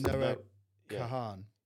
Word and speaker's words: Noah Kahan: It um, was Noah Kahan.